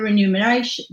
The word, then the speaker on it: remuneration: the remuneration, I always spell that, say that wrong. Because it's the N and the M around the other way. You think it's going to be like number.